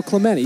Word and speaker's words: Clemente: Clemente. – Roberto Clemente. You've never. It's pronounced both ways. I've heard it both ways.